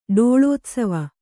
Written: ಡೋಳೋತ್ಸವ

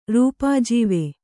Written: ರೂಪಾಜೀವೆ

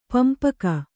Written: ಪಂಪಕ

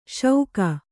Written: ಶೌಕ

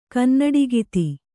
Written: ಕನ್ನಡಿಗಿತಿ

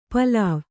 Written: ಪೋಲ್ವೆ

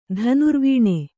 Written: ಧನುರ್ವೀಣೆ

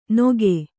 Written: ನೊಗೆ

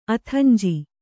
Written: ಅಥಂಜಿ

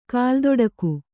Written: ಕಾಲ್ದೊಡಿಗೆ

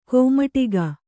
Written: ಕೈಮಡಗು

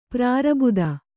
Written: ಪ್ರಾರಬುದ

